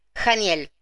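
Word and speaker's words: Haniel: Bueno, con, es el arcángel Janiel se escribe con H, pero se pronuncia Janiel.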